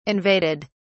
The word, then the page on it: invaded: British English